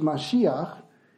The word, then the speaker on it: Mashiach: In Hebrew, it's Mashiach.